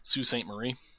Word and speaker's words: Sault Ste. Marie: Well, I selected it just to see if he had gone on the internet and checked how to pronounce, say, what looks like Sault Ste. Marie is actually, we have fucking weird pronunciations here, Sault Ste. Marie.